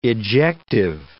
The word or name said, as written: ejective